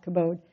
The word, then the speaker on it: kabod: I think I've heard other people pronounce it kabod, but I was taught kabod.